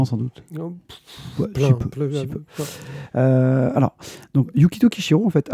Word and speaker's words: Yukito Kishiro: Euh, euh, Yukito Kishiro. Pas mal. – Voilà, Yukito de suite, mais le Kishiro pas du tout.